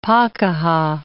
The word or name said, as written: pakeha